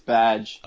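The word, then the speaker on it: B'dg: Of course, I think it's Badge. – Oh, that's right. I keep forgetting how technically we're supposed to pronounce it. Just remind me once in a while. So it's Badge.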